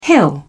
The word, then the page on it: hill: British English